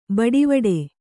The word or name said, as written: ಬಟ್ವಾಡೆ